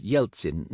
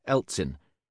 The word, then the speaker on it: Yeltsin: An E at the beginning of a Russian word is usually pronounced Ye, thus Yeltsin, not Eltsin, but Mount Elbruz, not Mount Yelbruz, because in Russian the E in this case is a different letter.